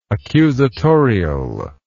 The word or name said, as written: accusatorially